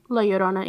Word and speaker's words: La Llorona: Llorona? – La Llorona, yeah.